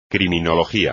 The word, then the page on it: criminología: espagnol